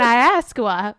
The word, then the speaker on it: Ayahuasca: It looks like it's spelled Ayahuasca, but it's pronounced Ayahuasca. – Ayahuasca. – Like almost like with a W, like Ayahuasca.